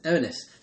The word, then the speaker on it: Erinyes: A common image from the same tradition is that of Eurynice, I think that's pronounced, E-R-I-N-Y-E-S, yeah, I think it's Eurynice, symbolising consuming guilt and qualms of conscience.